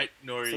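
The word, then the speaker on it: Aït-Nouri: So new boy, Ait Nouri. I hope I'm pronouncing that right. – Yeah. Such a cool Ryan name. Ait Nouri.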